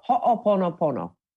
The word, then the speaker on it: Ho'oponopono: Ho'oponopono.